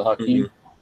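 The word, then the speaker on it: Hakim: I've seen people to use maybe it was MG13 mags and modify them for the Hakeem. Mm-hmm. It would be cool. I just haven't got around to doing it. – Yeah, and Flopping Garbage says you said it correctly.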